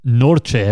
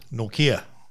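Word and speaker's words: Nortje: And Anrich Norche, have I said that right? – Norkia.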